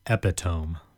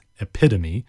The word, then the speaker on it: epitome: Our first word is epitome, or if you're my sister, you pronounce it epitome. She was once studying for the GRE, and she was going through all these words, and she doesn't read a lot. My sister's very intelligent, especially scientifically, but she came across this word, and she thought it was epitome, because it looks like epitome, but it's actually pronounced epitome. It's spelled E-P-I-T-O-M-E. It's a noun, epitome, E-P-I-T-O-M-E, fairly phonetic,